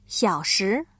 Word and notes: 小时: xiǎoshí

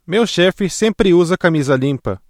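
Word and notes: camisa: Brazilian Portuguese

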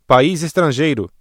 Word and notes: país estrangeiro: ブラジルポルトガル語

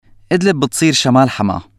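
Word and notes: شمال: Syrian Arabic

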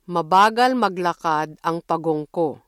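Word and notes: mabagal: Filipino

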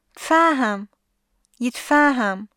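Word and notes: تفاهم (يتفاهم): Syrian Arabic

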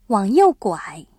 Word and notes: 拐: guǎi

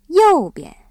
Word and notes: 右边: yòubian

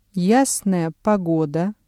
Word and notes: ясная погода: Russian

